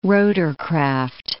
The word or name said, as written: rotorcraft